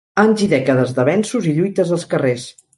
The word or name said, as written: lluites